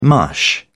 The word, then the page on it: mush: British English